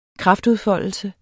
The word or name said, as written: kraftudfoldelse